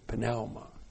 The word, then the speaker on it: pneuma: plus the genitive singular of pneuma. You probably would say pneuma, but no Greek would say pneuma. He would say pneuma. And I'm not Greek, but the Norwegians say pneuma too.